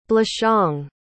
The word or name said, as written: blachong